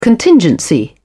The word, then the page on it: contingency: British English